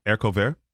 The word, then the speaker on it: haricot vert: What is it? – Haricot vert. – Is that how you say it? – Yeah.